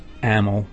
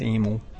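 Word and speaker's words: Amyl: Playing with, how do you say it, Emil and the Sniffers? Amel? – Amel. The Jersey in me says Emil.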